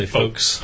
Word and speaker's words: folks: Do you pronounce the L in folks? – I don't. – You just say folks. – Folks. I say folks.